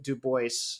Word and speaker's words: Du Bois: And he he he very pointedly pronounced it Du Bois uh, as a way of sort of claiming his Americanness and his uh, American blackness, and not alluding to maybe the uh, European aristocratic feel that it might give.